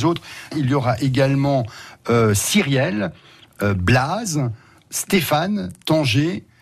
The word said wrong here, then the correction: Tanguay: Tanguay, ça se prononce Tanguay.